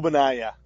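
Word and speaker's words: Ogbonnia: And I like Abayani. Did I say it right? – No, not at all. Obanaya.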